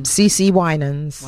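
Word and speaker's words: CeCe Winans: Is it pronounced C.C. Winans? – Yeah, C.C. Winans.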